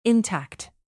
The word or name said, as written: intact